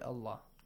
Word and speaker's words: Allah: With the letter lam in the word Allah. – It is Allah is pronounced heavily.